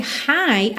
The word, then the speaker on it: high: Lembra que esse high, H-I-G-H, tem exatamente a mesma pronúncia do high. Hi, hello, somente H-I, ok?